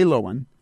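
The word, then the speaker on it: Loewen: Lowen, and that's spelled J-L-O-E-W-E-N. That's my name, Jim Lowen, J. Lowen, zoo, like the animal, Z-O-O, dot U-V-M, because I used to be at the University of Vermont, dot E-D-U.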